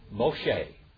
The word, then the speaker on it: Moshe: How do you say Moses in Hebrew? Moshe. Moshe, and what does Moshe mean?